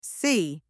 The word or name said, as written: see